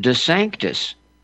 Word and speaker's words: DeSantis: the way DeSantis himself pronounced his own name as recently as just a couple years ago. He he pronounces it DeSanctis, the sanctus.